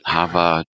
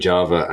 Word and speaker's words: Java: Colors by, and I'm sure I'm going to mispronounce it, Hava Tartaglia, I hope. – I think you did well. I've been saying Java and got corrected recently, so I think you're right.